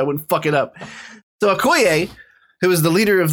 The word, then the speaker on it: Okoye: Well, how do you pronounce it? Okoye. That's right. Okoye.